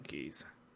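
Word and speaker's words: Keyes: Captain I think Keys. I've said it right. It's K-E-Y-E-S. – Yeah, it is Captain Keys.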